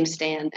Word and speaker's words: Stan: Kimberleigh, last name Stan, S-T-A-N.